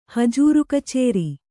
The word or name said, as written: ಹಜೂರುಕಚೇರಿ